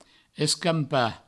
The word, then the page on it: escampar: Provençal; escampa